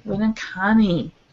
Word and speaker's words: Winneconne: Another email that we received was from Pastor Ben Berger, who's at St. Paul's in Winneconne, Wisconsin. – Winneconne.